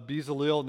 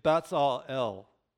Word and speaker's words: Bezalel: So technically, that you say Betzalel, Betzalel, it's a, it's a TS sound, Betzal the, the Z there.